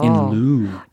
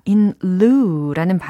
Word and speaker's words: in lieu: in lieu. – in lieu라는 발음인데 in lieu. – in 다음에 L I E U라는 철자입니다.